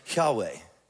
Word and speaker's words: Yahweh: So in order to pronounce it, we pronounce it Yahweh.